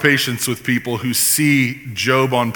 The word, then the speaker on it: Job: And so our graphic for this series puts the macron over the letter O, you know, the long O, so that you can remember it's Job.